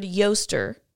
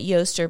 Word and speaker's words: Eostre: Okay, you say this word Yoster, but it's spelled E-O-S-T-R-E. – That looks like Easter. – Mm. But you technically would say it Yoster, but it looks like Easter.